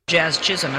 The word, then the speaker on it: Jazz Chisholm: Jazz Chisholm, and I don't know who that is. – No, she said it right. – Jazz Chisholm in 2021 right.